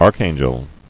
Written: Archangelic

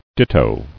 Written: Ditto

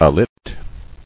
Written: Alit

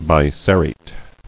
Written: Biserrate

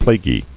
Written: Plaguy

